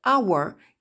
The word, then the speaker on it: our: Concernant la prononciation de our, qui est toujours difficile, sauf si on connaît la phonétique, pensez au mot que beaucoup d'entre vous connaissent, le mot flower, qui est une fleur.